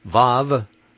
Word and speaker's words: Vav: Levy is spelled L-E-V-Y. Page 18 of the alphabet. Here it reads, Vav, V as in vat, V-A-T.